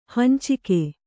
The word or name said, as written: ಹಂಚಿಕೆ